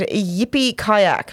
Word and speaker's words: Yippie Kayak: So we have episode 10 called Yippie Kayak.